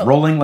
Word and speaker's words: Rowling: It's rolling like bowling.